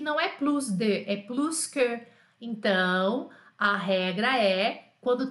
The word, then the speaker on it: plus que: É o plus que e se pronuncia o S. Sempre vai ser plus que, Jana, plus que alguma coisa. Então, nessa comparação, sim, pronunciamos o S.